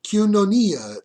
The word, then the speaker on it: koinonia: Koinonia is, as far as I can tell, the way it's pronounced. My Greek pronunciation software keeps telling me that I'm pronouncing it wrong. Here, listen closely. See if you can hear my computer play this. – Kyunonia, kyunonia, kyunonia. – Kyunonia, it says. I think my software is more Italian than it is Greek.